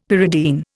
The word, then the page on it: pyridine: Indian